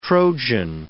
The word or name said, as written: Trojan